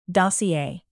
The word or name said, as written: dossier